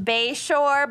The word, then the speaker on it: Basehor: She did tell us is pronounced Bayshore, but she also told us to just have at it, and it is literally spelled Basehor.